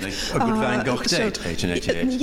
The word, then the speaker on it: Van Gogh: No, I'm saying Van Gogh because that is how you pronounce it. But that's obviously difficult for non-Dutch speaking people.